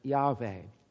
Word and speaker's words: Yahweh: which was probably pronounced something like Yahweh.